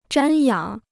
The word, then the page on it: 瞻仰: zhān yǎng